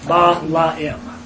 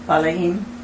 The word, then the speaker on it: Balaam: So it's B-A-L-A-A-M. So actually, Balaam should be three syllables. Ba-la-im. – Ba-la-im.